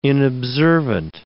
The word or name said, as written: inobservant